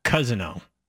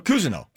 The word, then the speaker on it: Cousineau: It, it, it, Tom Cousineau. Oh, no, Cousineau. – Cousineau. Tom Cousineau. Yeah, yeah, yeah. Tom, Tom there you go. That was close.